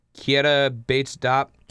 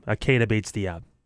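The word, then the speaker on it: Keita Bates-Diop: Kieta Bates-Diop. – You butchered that name. Kieta Bates-Diop. He played for Ohio State.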